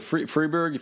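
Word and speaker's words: Fribourg: Yeah, so Freiburg if you're speaking French, Freiburg if you're speaking German, which people do both of here. So however you pronounce it, it's probably fine.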